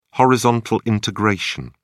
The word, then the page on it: horizontal integration: British English